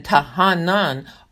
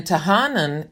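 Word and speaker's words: tahanun: So the two nouns, which I believe are pronounced Tahena and Tahanan, are translated supplication.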